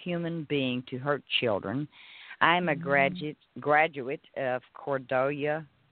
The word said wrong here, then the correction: Cordia: It's pronounced the way it's C-O-R-D-I-A. Cordia.